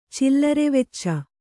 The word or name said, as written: ಚಿಲ್ಲರೆವೆಚ್ಚ